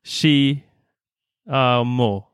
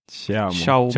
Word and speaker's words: Xiaomi: Men sen beställde jag även, eh, hur uttalar vi det nu? Chi Amo. Nej? Xiaomi. Xiaomi.